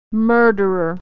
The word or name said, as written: murderer